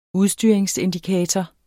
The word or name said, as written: udstyring